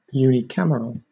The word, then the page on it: unicameral: British English